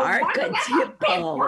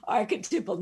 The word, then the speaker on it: archetypal: No, archetypal. And then it say it with me now. archetypal.